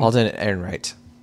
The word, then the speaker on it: Ehrenreich: Alden Ehrenreich.